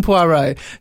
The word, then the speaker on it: Poirot: Yeah, you don't pronounce the T in Poirot, so it doesn't quite work.